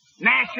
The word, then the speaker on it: gnash: Nash is spelled G-N-A-S-H, and the G is silent preceding the N.